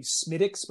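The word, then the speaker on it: Smithwick's: It's pronounced, I believe, Smittix, but it's Smithwix. Okay.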